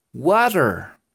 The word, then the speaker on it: water: Y se pronuncia water. Como si la T fuera una especie de R, algo así.